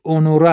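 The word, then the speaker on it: Honoré: تلفظ درستش اونور است. یه اسم مردون است. زنونش یه حرف ای اضافه در انتهاش داره، اما با همون تلفظ.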